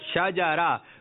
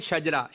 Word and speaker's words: شجرہ: یعنی شین پر زبر ہے, جیم پر بھی زبر ہے اور را پر بھی زبر ہے. ٹھیک ہے شاجارہ. کچھ لوگ کہتے ہیں شجرہ, شجرہ شریف, شجرہ شریف. تو یہ تلفظ صحیح نہیں ہے.